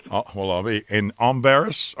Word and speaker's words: Embarrass: is the name of the river Embarrass? – That's what it looks like. It's actually pronounced Ombrus.